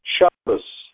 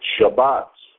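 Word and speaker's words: Shabbat (שבת): Shabbat is pronounced Shabbos by the Ashkenazim. But in the Sephardim and in the Hebrew language as spoken today in the modern state of Israel, it's Shabbat.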